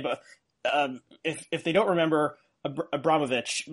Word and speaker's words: Abramović: forgive me if I'm saying that wrong, you can pronounce it correctly. – Abramović, and Donald Trump.